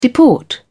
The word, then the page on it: deport: British English